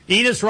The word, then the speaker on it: Enes: Enes rhymes with penis.